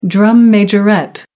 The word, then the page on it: drum majorette: American English